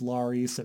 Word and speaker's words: Larys: His name is Larys. It rhymes with Varys.